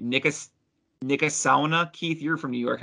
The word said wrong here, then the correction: Niskayuna: Niskayuna? – Oh, God.